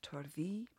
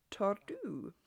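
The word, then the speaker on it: Torddu: And thank you very much to everyone who pointed out that it is pronounced Torthy and not Tordu.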